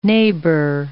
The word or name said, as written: neighbor